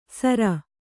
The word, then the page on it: ಸರ: kannada